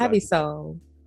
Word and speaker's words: Sole: The first song is going to be Nancy's Choice, Dream Girl by, is it Ivy Soul or Ivy Soleil? I feel like it's Ivy Soul. – Okay.